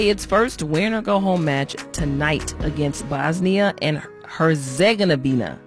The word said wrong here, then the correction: Herzegovina: I probably said that wrong. Let me try one more time. Herzegovina, there it is, at 8 p.m.